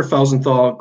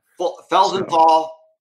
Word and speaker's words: Felsenthal: It's Sugar Felsenthal Grace and Helsinger. – Felsenthal.